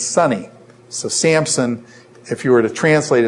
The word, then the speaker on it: Samson: You guys call him, how many of you pronounce this guy's name Sampson with a P in it? Samson, okay? We pronounce it with a P. Do you realize, is there any P in there? No.